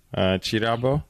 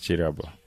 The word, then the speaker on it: Kirabo: Chirabo. – Yeah, gift. – Yes, gift. It means gift in Luganda. – Yeah. – It's written with a K, like Kirabo, pronounced Chirabo.